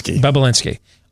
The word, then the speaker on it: Bobulinski: So either, and again, how, how's the last name pronounced? I always forget. Um, Bobulinski.